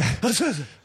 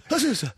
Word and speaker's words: Azusa: Did you know that Azusa sounds like a sneeze? – Yeah. – Azusa. Azusa. – Did you know that Azusa? I didn't know this until recently. A-Z-U-S-A, right? That's how you spell it.